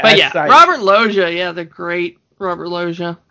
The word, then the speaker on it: Loggia: speaking of Sykes, Robert Loggia, is that how you pronounce his name? – It's R, as in Robert Loggia. – Loggia, thank you.